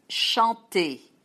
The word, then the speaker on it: chanter: like chanter. All those verbs are regular, and most come from Latin. Chanter, ER, you pronounce it, E.